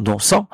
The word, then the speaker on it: Noncent: c'est Jean Non-Sang, notre cher ami Non-Sang. Ça s'écrit presque comme innocent, N-O-N-C-E-N-T.